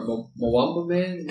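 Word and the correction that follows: Matumbaman: Matumba Matumba Man.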